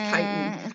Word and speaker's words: chitin: They've got like several layers of what do you call it, chitin or chitin? I think I've heard it pronounced chitin. – Sure, chitin sounds right.